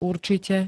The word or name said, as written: určite